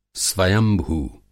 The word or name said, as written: स्वयंभू